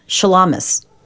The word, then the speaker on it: Shulamis: So, for example, when Avram Goldfaden came to the United States, and he wrote an operetta called Shulamis, which is the Yiddish pronunciation of Shulamit, the Hebrew heroine, ancient Hebrew heroine.